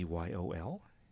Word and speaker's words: YOL: pronounce it Yol or Y-O-L? – Y-O-L. – Y-O-L, okay.